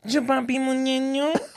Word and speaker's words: Chupapi Mnyenyo: Chupapi. You have to say it like that. Chupapi Mnyenyo.